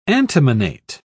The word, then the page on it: antimonate: British English